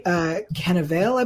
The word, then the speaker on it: Cannavale: I want to talk real quick about Kyle Novacek, because that actor, Bobby Cannavale, I believe, is how it's pronounced. Yes.